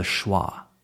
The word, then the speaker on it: schwa: It's called the schwa, spelled S C H W A.